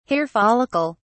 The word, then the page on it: hair follicle: British English